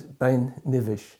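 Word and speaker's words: Beinn Nibheis: In Gaelic, Ben Nevis is Bain Nivish, and some people think it comes from the Gaelic word nuv, meaning saint or heavenly, so it's a heavenly mountain.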